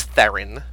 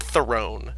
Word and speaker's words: Theron: It's Charlize Theron, not Throne.